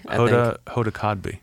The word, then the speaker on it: Hoda Kotb: Cosby or Kotb? – Hoda Kotb. Yeah, yeah.